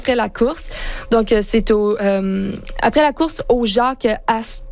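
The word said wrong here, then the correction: Jack Astor's: Astor, je l'ai prononcé vraiment français, là, mais je pense Jake Astors.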